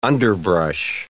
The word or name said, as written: underbrush